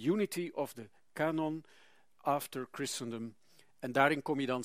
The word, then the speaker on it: Christendom: Een inleidend hoofdstuk over The God of Israel After Christendom. Christendom lees je, maar moet natuurlijk op zijn Engels uitspreken.